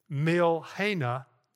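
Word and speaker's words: Milhanah: The next one is Milhena, Jehovah Milhena. Let me spell that for you. It's M-I-L-H-A-N-A-H. M-I-L-H-A-N-A-H. Jehovah Milhena.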